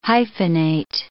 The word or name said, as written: hyphenate